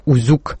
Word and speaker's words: Узюк: Всю ночь я смотрел длинный фильм с названием «Узюк». Ударение на втором слоге.